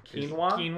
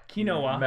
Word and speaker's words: quinoa: The first time I saw that word, because that word is spelled Q-U-I-N-O-A, mm-hmm. – and the first time I saw that, I already knew what quinoa, I knew that word.